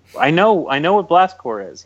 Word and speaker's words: Blast Corps: Blast Corps? Stop saying corpse. It's Corps? Blast Corps. – Like Marine Corps. – Yeah, C-O-R-P-S is Corps.